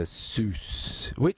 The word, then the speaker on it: Seuss: It is Dr. Seuss. The actual correct pronunciation, from what I understand, is Dr. Seuss or Zeus. But he got around to people calling him Dr. Seuss because it rhymed with Mother Goose.